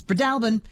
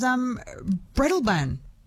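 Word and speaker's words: Breadalbane: Katrina in Roslyn says Fiona's correct. It's Bredalbin. Louise in Nowra says, Jess, I've only ever heard it as Bredalban. Oh, I'm probably mispronouncing the mispronunciation. She said she learnt it from a hotelier in Yass.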